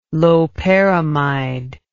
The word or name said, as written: loperamide